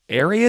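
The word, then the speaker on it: Aire: Airy. It's actually Air.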